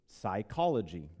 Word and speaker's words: psychology: And by the way, soul in the Greek, psuche, is where we get the word psychology, right? The P is silent, right?